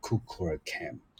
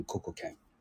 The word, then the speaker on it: Kukulkan: 这时候，就是托特尔克文明就开始跟玛雅文明做结合，所以呃，玛雅人就把呃羽蛇神以他当地的就是尤加敦半岛语就叫做 Kukulkan 然后，呃，这个样的念法其实很重要，就是不要把它念成呃 Kukulkan，就是它有不同的意思，所以比较建议大家就直接念成 Kukulkan。